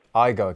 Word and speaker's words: Igo: Matt, a point you have been making on Twitter and you've been quoting your colleague Brett Ego, that's how I pronounce it, isn't it? – Brett Ego. – Igo, excuse me, who's been a coach down the years and around the game for a long time.